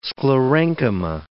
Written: sclerenchyma

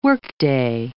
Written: workday

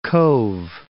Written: Cobh

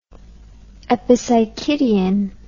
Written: epipsychidion

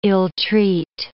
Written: ill-treat

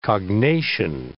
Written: cognation

